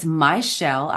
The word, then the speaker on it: Myshel: So it's Myshel. I'll spell it for you. Okay. M-Y-S-H-E-L. And then my last name is Wilkins. W-I-L-K-I-N-S.